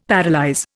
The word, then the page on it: paralyse: Indian